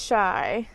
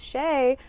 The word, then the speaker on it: Chey: It is Chey. And welcome back, or welcome to Coffee with Chey, or Chey, however you pronounce it.